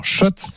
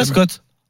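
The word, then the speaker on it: Schott: Je vous rappelle également que demain soir, dans le quiz de l'After, on vous offre votre bomber Shot. – Oh. – Parce que c'est comme ça qu'on prononce. – On dit pas Scott.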